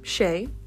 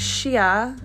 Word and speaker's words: Shea: I introduced myself as Shay Like the Butter because that's how it's spelled, and a lot of people pronounce my name wrong. My whole life, literally. As soon as they look at my name to read it, it's Shea, Shea, She. Sometimes like the A doesn't even exist.